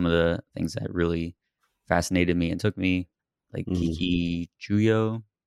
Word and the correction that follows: Kikuchiyo: Butchered the name, I'm sure. But Toshiro Mifune's character. – It's Kikuchiyo, isn't it? – Kikuchiyo.